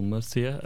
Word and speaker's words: Murcia: So it's in these two sort of smallish cities in southern Spain called Murcia and Cartagena.